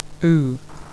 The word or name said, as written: oo